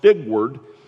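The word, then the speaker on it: stigweard: The word stewardship originates from an Old English stigward is the word, an Old English word pronounced stigward.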